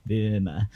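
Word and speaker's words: Böhme: Yeah, an example of that would be the work of Jacob Burma, which sounds a bit like Burma, but it's actually spelled B-O with an umlaut, H-M-E, because German pronunciation is a fuck. – Nice. – Burma.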